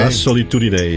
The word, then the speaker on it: La Solitudine: La Solitudine is het. – Ja, zo. – Hoor je het nou? – Zo moet je het uitspreken. Ja, dat is Italiaans.